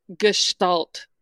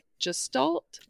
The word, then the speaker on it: gestalt: Saying, it's a hard G. – No, Tara was like gestalt. And I was like, I got to look up how to say this. And I looked it up. And sure enough, very German.